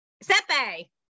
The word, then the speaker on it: Sepe: Is it Sepe? – Sepe. – It's Italian, right? – Well, half of the people in my family pronounce it Sepe.